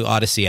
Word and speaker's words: Audacy: That, of course, includes Apple, it includes Google, Spotify, and the all-new Odyssey app. Odyssey is spelled A-U-D-A-C-Y.